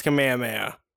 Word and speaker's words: Kamehameha: And if you're friends with Hawaiian people, it's Kamehameha. – I've been to Hawaii. I don't know if that counts. – Why is that, though? – Because they pronounce it that way because it's based on the original king of Hawaii.